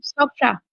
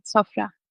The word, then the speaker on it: Sofra: Nein, Moment, man spricht es anders aus. Kannst du es nochmal aussprechen? – Sofra. – Sofra. Aber ist die Betonung am Ende und man macht ein rollendes Rhythmus? Ja, in türkischen Wörtern ist die Betonung oft am Ende.